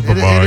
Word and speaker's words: Brevard: I always say Brevard, but it is Brevard.